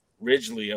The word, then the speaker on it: Ridglea: You kick it off Thursday night at the help me pronounce it because I don't want Ridglea to say it Theater. – Ridglea, okay. – You got it.